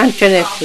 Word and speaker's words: Antoinette: Yeah, Antoinette Woodson. – How do you spell it? – A-N-T-O-I-N-E-T-T-E.